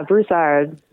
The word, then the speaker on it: Broussard: Yeah, Broussard.